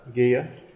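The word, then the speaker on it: Geyya: Is it pronounced Gaya? – Gaya.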